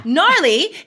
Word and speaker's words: gnarly: It starts with a G. Like, what is that about? I mean, English is made up of so many other languages. I'm totally going to figure this out soon. So yeah, gnarly is spelled G-N-A-R-L-Y.